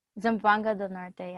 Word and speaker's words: Zamboanga del Norte: Zamboanga del Norte, yeah.